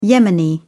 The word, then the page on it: yemeni: British English